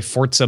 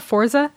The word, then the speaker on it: Forza: I occasionally play Forza Motorsport or Hearthstone. – Oh, right. Yeah, you were playing. Everyone's playing. It's not Forza.